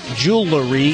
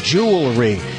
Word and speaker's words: jewelry: It's not jewelry, it's jewelry.